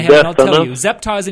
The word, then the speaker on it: Zeptah: The name is Zeptah. That's how I'm pronouncing it. Z-E-P-T-A-H is how it's spelled in the Kirtland era manuscripts, Zeptah. That is unquestionably a dead ringer, absolutely attested, 100% Egyptian name, Zeptah.